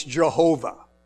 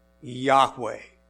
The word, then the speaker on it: Yahweh: And if you're from the South, it's Jehovah. But I pronounce it Yahweh. Yahweh.